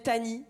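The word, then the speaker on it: Tahnee: Donc je me présente, je m'appelle Tani, ok. Tani, ça s'écrit T-A-H-N-E-E. C'est un prénom d'origine indienne et ça veut dire en indien, oui, je suis une grosse lesbienne.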